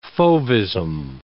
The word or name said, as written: fauvism